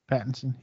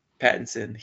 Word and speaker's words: Pattinson: I loved everything about the movie except for Robert Pattinson. I think I'm pronouncing his name right. – Pattinson, yeah. – Pattinson.